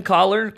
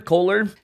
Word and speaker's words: Koller: Um, have the pleasure of talking to Ben Collar, Koller, depending on how and where you're from, that'll be how you pronounce it.